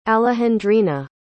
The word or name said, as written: alejandrina